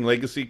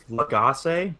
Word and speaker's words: Legace: Oh, and am I pronouncing Legacy correct? Because Hetty and Randy were pronouncing it not necessarily Legacy. – I read it as Legace, but I think that's wrong. – Okay, yeah, they might have been saying Legace.